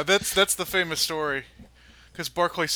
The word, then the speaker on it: Berkeley: I think it's pronounced Berkeley. – Is it pronounced Berkeley? – I've always heard Berkeley. – That's how the Brits pronounce it.